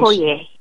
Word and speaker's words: Okoye: Okoye.